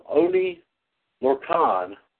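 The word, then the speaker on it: Oney Lorcan: Today is the 31st birthday of Oni Lorcan, is how you pronounce that name, known to indie wrestling fans as Biff Busick.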